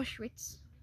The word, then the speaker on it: Auschwitz: Yeah, Auschwitz.